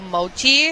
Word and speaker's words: mochi: A strawberry mango mochi.